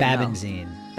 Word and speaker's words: Babenzien: Babenzien.